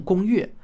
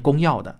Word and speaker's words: 公钥: 不过很奇怪的是啊，字典上说这个要读成公钥，实在是很别扭啊。因为我身边百分之九十九的人都是念公钥的。